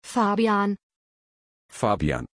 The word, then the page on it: fabian: German